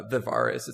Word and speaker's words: Vivarus: So my full name is Alex McKinley, and you pronounce my screen name Vivarious. It's more of like a Latin pronunciation than anything else. I don't know why, but people just decide to put a random I somewhere in it. There's no I.